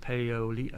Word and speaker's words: Paoli: Battle of Paoli, is that how you pronounce it? Paoli, yeah.